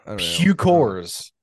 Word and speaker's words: Puchkors: So for anyone listening at home, it's Puckors, right? – Yeah. So take your guesses right now how you would pronounce it. And then I'll say the real thing. – Puckers. – Get that one a lot. Get that one a lot. – Okay. I don't know. – Puckors.